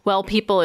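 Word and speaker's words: W3LL People: So Well People is awesome. It's spelled W3LL.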